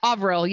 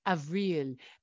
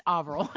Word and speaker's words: Avril: Avril, yeah. Avril, sorry. It's such a cool name that I always want to say it like Avril, but it's Avril.